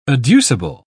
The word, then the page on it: adducible: British English